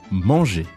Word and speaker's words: manger: Manger.